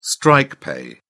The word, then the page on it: strike pay: British English